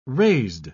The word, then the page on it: raised: British English